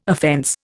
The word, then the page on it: offence: Indian